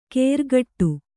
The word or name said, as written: ಕೆರ್ಗಟ್ಟು